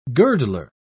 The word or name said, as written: girdler